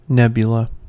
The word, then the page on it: nebula: American English